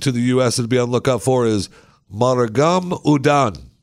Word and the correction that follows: Marugame Udon: And I know I'm saying that wrong. I know I am. Marugame Udon.